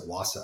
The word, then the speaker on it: Vasa: There was this ship in the 1600s, and it was called the Vasa, V-A-S-A. I think they pronounced it Wasa because it was Swedish.